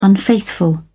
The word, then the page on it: unfaithful: British English